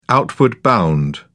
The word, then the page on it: outward bound: British English